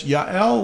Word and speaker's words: Yael: And here with us today, again, coming from Oak Lake Trails Nature's Park in Depew, Oklahoma, we have Miss Yael with us.